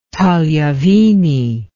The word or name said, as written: tagliavini